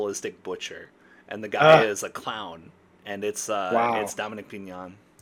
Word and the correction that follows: Dominique Pignon: I just wanted to make sure I'm getting his name right. Yeah, Dominique Pignon.